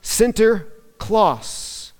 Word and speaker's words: Sinterklaas: And in Dutch, St. Nicholas is pronounced Sinterklaas, which then becomes known as today modern Santa Claus.